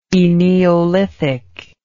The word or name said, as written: eneolithic